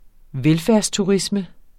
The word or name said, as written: velfærdsturisme